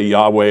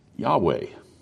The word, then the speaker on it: Yahweh: Yahweh, Jehovah, I asked a professor, what's the right pronunciation of that four letter Hebrew word? And he said, well, about half of people say Yahweh and about the other half say Jehovah. But it's that Yahweh.